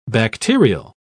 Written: bacterial

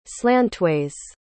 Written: slantways

